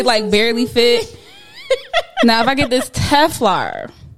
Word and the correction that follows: Telfar: Telfar, I always say it wrong. – Me too. – Yeah. – Telfar. Yeah.